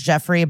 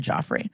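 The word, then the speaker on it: Geoffrey: I know it's Jeffrey, but if you're going to spell it like that, I'm going to call you Joffrey.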